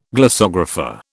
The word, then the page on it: glossographer: British English